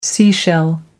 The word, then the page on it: seashell: American English